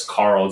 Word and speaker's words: Carl: My Twitter handle is Carl Za, just my name in one word.